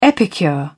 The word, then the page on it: epicure: British English